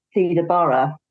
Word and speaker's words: Theda Bara: Like, I'm probably going to say her name wrong now, Theda Bara.